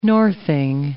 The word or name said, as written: northing